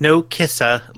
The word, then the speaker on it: Narcissa: Well, first of all, you kind of butchered her name. It's Narcissa Cassius. – Oh, sorry. Nakisha Cash. – It's like no kissa, like you're not kissing her.